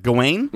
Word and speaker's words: Gawain: Gawain. I don't know if I'm pronouncing that. Gawain.